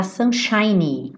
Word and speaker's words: sunshiny: Não esquece desse Y, tá? Sunshiny. Porque ele tá fazendo adjetivo, lembra que eu falei pra você? Sunshine, termina com E e o E é mudo, seria o brilho do sol, tá? E sunshiny tá fazendo adjetivo. Então, esse Y tem que aparecer.